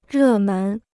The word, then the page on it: 热门: rè mén